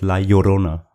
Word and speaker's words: Llorona: Geschrieben ist es La Llorona, also mit Doppel-L. Aber die Spanier, die Mexikaner sprechen das La Llorona, also genau.